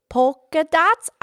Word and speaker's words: polka: Polka dots. I was always saying it wrong forever, and then I figured out it has an L.